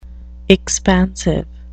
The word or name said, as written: expansive